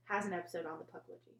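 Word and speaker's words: Pukwudgie: And is that how you say it? – Puckwudgie. – Puckwudgie. Yeah.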